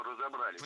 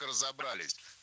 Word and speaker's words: разобрались: Ну, как разобрались? Насколько это возможно? – Разобрались неверно. Вспоминаем про всемирное тяготение части «с» к ударению и произносим правильно «разобрались».